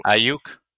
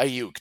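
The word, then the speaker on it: Aiyuk: Is that how you say his name? I know you were a fan of that guy too. – Ayuk. – Yeah, Ayuk. That's it.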